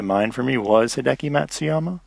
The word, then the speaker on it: Matsuyama: What about, uh, I think our the one takeaway from last weekend's tournament was Hideki Matsuyami Matsuyama. I'm really bad at pronouncing his name. I think say said different every time, but he's he putted really badly uh, last last weekend, and he's been playing well.